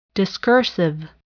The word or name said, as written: discursive